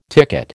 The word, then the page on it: ticket: American English